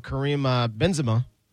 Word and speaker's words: Karim Benzema: Soccer legends right now: Cristiano Ronaldo and Karim Benzema. Did I say it right? – Yeah.